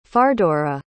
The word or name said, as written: fardoragh